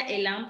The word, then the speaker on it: Elain: Se pronuncia Elan, pero se escribe Elain con H.